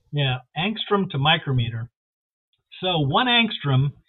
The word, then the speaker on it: angstrom: And how do you pronounce it? Engram? – Angstrom. – Yeah. Angstrom. So, angstrom.